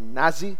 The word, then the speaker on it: Nazi: My son, Hakim, he was telling me, That's not how you don't pronounce the word Nazi. Nazi? And like, no, it sounds like nasty.